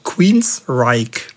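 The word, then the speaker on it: Queensrÿche: Also Queenstrike. Und übrigens, seinen Vornamen schreibt man ja G-E-O-F-F, aber man spricht nicht Joff, wie viele Leute das tun, sondern Jeff.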